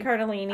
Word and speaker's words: Cardellini: Cardellini.